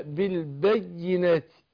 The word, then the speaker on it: بالبينات: فهنا الياء مشددة البينات، ما ينفعش يقول ولقد جاءتهم رسلهم بالبينات، لا بالبينات بالبينات، واخد بقى ايه بدون مبالغه اشددها بدون مبالغه بالبينات.